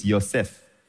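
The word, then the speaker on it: Yosef (יוסף): Like my name is Joseph. In Hebrew, it's Yosef. Every J is a Y, actually. That's the correct pronunciation. So this Yud He Vav He. Then in your Bible, it is capital L-O-R-D.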